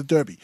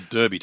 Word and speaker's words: Derby: And I like that they call it a derby too, not a derby. – Well, see, you know, I say derby because that's how it's spelled. – No, that's how they say. – Yeah. – In WA. – And apparently there's a small country town called Derby in WA, and that's how it's pronounced.